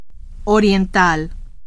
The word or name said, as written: oriental